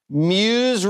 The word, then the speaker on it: Meuse: 25,000 people lined up in the Meuse River. Is that how you pronounce it? – Yes.